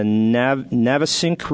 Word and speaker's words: Navesink: Caught in the Navesink River. Is that how you pronounce it? – That sounds about right.